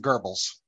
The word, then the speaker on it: Goebbels: Okay, so it's well, I pronounce it Goebbels. – Okay. – So yeah. So like an R that doesn't exist.